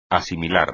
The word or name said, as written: asimilar